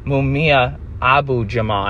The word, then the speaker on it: Mumia Abu-Jamal: Like Mumia Al-Jamal, Kevin Rashid Johnson, excuse me, Mumia Abu-Jamal, I knew I said that wrong.